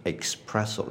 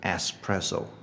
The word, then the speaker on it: espresso: OK，如果你念成 espresso 的话，就是后面的音啦。可是正确的应该是 espresso，因为这个字是 ESP。开始的不是 e x p，OK，、okay? 如果 x 的话，我们通常都在后面。那如果 s 的话，其实我们是在舌尖上面就可以解决了，OK。所以这个字念 espresso，不是 espresso。